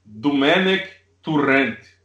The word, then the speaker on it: Domènec Torrent: Mas a pronúncia, né, seria algo parecido com Domènec Torrent ou coisa parecida com isso.